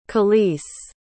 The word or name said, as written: kelis